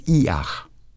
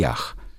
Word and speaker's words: Yach: Der heißt Y-A-C-H. – A-C-H. – Und wird, wird gesprochen Iach. So wird er ausgesprochen. Okay. Iach.